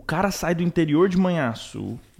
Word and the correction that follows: Manhuaçu: Manhuaçu, não consigo nem pronunciar, né? Manhuaçu, perdão.